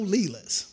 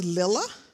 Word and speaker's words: Lela: And nobody said it right. You know, most of you either say Lil, which you know is my nickname, or you say Leela, you say it right. But where I grew up, I mean, they butchered it. Oh, yeah, I was Lilla. I'm still Lilla. There's no I's in my name. L-E-L-A.